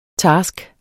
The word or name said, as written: task